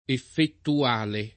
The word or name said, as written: effettuale